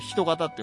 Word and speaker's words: 人形: そしてね、まあ、その身の汚れを、ひな人形とかその神をこう人の形に切り抜いた人形と書いて人型って読むんですけど、